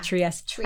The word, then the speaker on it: Trieste: including Marghera or Trieste. Did I say that right? – Yeah. – Trieste.